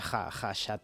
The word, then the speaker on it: Khachaturian: Khachaturian. – Khacha. – Aram, se escribe K-H-K-H-A, pero se pronuncia Khachaturian.